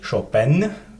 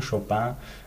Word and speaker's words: Chopin: Eigentlich Chopin, das wird nach der französischen Maniere ausgesprochen, Chopin.